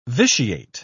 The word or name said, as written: vitiate